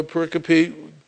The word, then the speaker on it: pericope: Okay, that's how you kids, if you have that word, you know, and you're looking at it, P-E-R-I-C-O-P-E. Okay, that's pronounced pericope. It's quadrisyllabic. Okay. Who knows what quadrisyllabic means? Four syllables, right? Okay, it's quadrisyllabic. It's not pericope. Okay. I mean, it looks like pericope to me, but it's pericope.